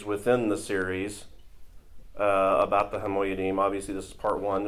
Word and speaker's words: HaMoyadim: So no, it's called HaMoyadim.